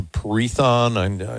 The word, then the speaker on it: permethrin: Might try that or I think it's a permethrin. It's P-E-R-M-E-T-H-R-I-N. Permethrin. – Permethrin? Okay. – Yeah. I forget really how to say it, to be honest with you. I call it permethrin.